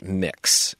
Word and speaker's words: Mx: And now we have MX pronounced mix. And it's a capital M followed by a lowercase x and a period. And you can use the title mix for anyone who wants to use it.